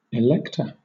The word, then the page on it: elector: British English